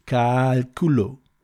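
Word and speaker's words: cálculo: you can't pronounce Calculo, because culo means something different. Look it up. You have to put the A on the long A on the first.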